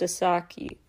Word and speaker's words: 4S4KI: Asaki, I apologize, last time I wasn't sure, it's spelled 4S4KI, pronounced Asaki.